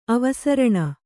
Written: ಅವಸರಣ

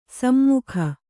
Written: ಸುಮುಖಿ

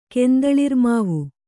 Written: ಕೆಂದಳಿರ್ಮಾವು

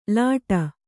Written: ಲೋಟ

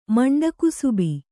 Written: ಮಂಡಕುಸುಬಿ

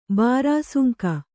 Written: ಬಾರಾಸುಂಕ

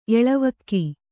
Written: ಎಳವಕ್ಕಿ

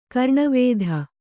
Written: ಕರ್ಣವೇಧ

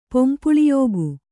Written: ಪೊಂಪುಳಿಯೋಗು

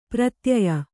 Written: ಪ್ರತ್ಯಯ

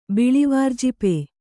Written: ಬಿಳಿವಾರ್ಜಿಪೆ

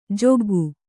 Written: ಜಗ್ಗು

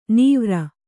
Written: ನವಿರು

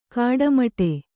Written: ಗಟ್ಟಿಮುಟ್ಟು

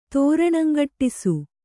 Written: ತೋರಣಂಗಟ್ಟಿಸು

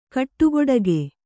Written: ಕಟುಗೊಡಗಿ

